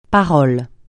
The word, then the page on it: parole: francais